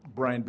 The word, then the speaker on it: Bryan: Bryan Baraniski, B-R-Y-A-N, Baraniski, B-A-R-A-N-I-S-K-I.